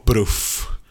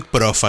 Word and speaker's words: Proof: O Proof. Prof. Creo que le dicen Prof aquí. – Sí, se escribe Proof. – Se escribe Proof, pero el Prof.